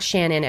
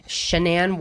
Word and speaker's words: Shanann: Okay, so Chris Watts, he's 33 years old, and he's married to a woman named Shanann. It's not Shannon. Everyone keeps saying it wrong. Shanann Watts.